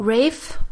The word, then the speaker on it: Ralph: Rafe? – Yeah. – Is it Rafe Fiennes? – I thought it was Rafe Fiennes, right? – Yeah. – Yeah, Rafe me too.